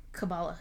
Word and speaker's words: Kabbalah: And then, I think you say Kabbalah? – Kabbalah. Can you spell it? – K-A-B-B-A-L-A-H. – Kabbalah. – There you Kabbalah. Go. I'll let you do it. I only say it once, so. – Kabbalah. – There you go.